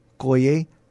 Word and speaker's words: Koye: And joining us now is Pastor Dr. Koye.